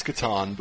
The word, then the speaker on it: Catan: I've especially noticed that is it Settlers of Catan has become quite intense. It's Catan, but yes.